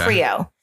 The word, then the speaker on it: Krio: K-R-I-O. – Okay. – Creole. Which sounds very much like Creole. – Yeah. – But it's spelled very much the way they'd spell it in patois.